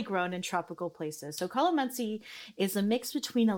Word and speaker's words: calamansi: Calamansi? – Calamansi. Oh my God. So did I say it right? – You did. Congratulations.